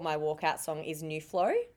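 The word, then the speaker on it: Nu Flow: New Flow is spelled N-U space F-L-O-W. And Big Brothers is spelled big, obviously, but Brothers is B-R-O-V-A-Z.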